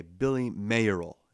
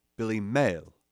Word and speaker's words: Billy Mayerl: I say Billy Mayerl, and his last name is spelled M-A-Y-E-R-L, Mayerl. But he's British, so they would say Billy Mayerl, you know, because British people say, you know, boys and girls.